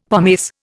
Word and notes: pomace: Indian

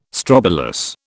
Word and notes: strobilus: British English

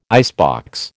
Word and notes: icebox: American English

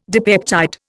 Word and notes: dipeptide: Indian

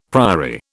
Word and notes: priory: British English

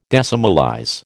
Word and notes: decimalize: American English

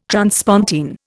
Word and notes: transpontine: Indian

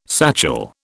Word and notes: satchel: British English

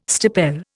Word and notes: stipel: Indian

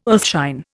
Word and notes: earthshine: Indian

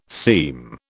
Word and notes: seem: British English

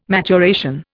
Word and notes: maturation: Indian

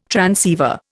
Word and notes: transceiver: Indian

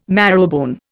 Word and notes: marrowbone: Indian

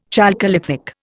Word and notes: chalcolithic: Indian

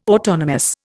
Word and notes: autonomous: Indian

